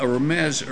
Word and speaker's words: Remez: In Jewish hermeneutics, there's a hidden message or a deeper meaning, which is called, the word is spelled R-E-M-E-Z, I'm just going to pronounce it, Remez.